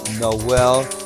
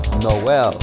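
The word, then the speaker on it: Noël: My name is Noel Michael Smith with an accent on the E. No L. Not Noel, Noel Michael Smith.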